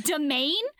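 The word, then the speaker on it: demesne: And when one of those words looks like it's Desmansis, and it's actually pronounced domain,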